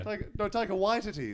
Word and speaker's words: Taika Waititi: Because I feel like everyone in the world was saying Taika Waititi, and then he did a YouTube video where he said his name, I think it was for Vanity Fair, and then the whole world went, oh, shit. – Mm. – That's how you say it.